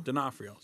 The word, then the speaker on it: D'Onofrio: DeForno. Is that how you pronounce his name? DeForno? – D'Onofrio. – Sorry. Yeah. Vincent D'Onofrio's in it.